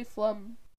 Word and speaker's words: phlegm: Tell them how you say phlegm. – Phlegm. It's phlegm. It's spelled phlegm.